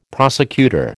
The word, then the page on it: prosecutor: American English